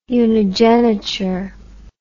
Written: unigeniture

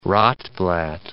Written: Rotblat

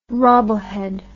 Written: rubblehead

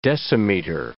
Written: decimeter

